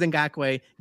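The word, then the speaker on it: Ngakoue: It is Ngakwe. I knew it was Ngakwe.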